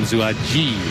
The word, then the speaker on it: Zuaji: Told I had the pronunciation incorrect on Adam's name, Zuaji. It's not a hard G.